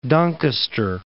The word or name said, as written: Doncaster